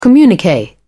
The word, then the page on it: communique: British English